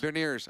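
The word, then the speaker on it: Beniers: In all honesty, I love Bernier. Or how do you say, is it Berniers? – Berniers.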